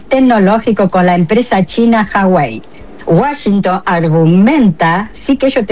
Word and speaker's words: Huawei: La empresa es Huawei. Acá la pronunciamos así.